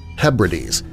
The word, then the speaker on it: Hebrides: My favorite was the Outer Hebrides, which we say Hebrides.